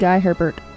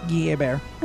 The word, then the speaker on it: Hebert: Guy Hebert. – That's how you pronounce that? – Yes. – I would have said Guy Herbert. – Yeah, it's Guy Hebert.